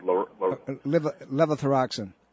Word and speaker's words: levothyroxine: Levothyroxine. – Yes, levothyroxine. – Okay, so levothyroxine and Synthroid, if you happen to be allergic to grain, you're going to be very sensitive to those two things. Levothyroxine and Synthroid are both derivatives from corn.